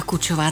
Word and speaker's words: Kučová: A mé jméno se ještě vyslovuje trošičku jinak a jsem Kučová. – Jana Kučová, tak teď už to máme jasno.